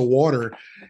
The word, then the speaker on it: water: The word water, W-A-T-E-R, you know, give me a glass of water. You pronounce it water, W-O-O-D-E-R. And that's absolutely correct.